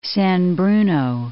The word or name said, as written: San Bruno